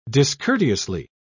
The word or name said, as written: discourteously